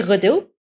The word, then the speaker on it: Gudu: See, I'm not even perfect in pronouncing it because I'm not a local, but every person says it, the locals say it in a very good way. So I would say Gudu.